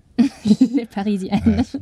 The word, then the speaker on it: Les Parisiennes: Les Parisiennes.